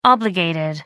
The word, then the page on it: obligated: American English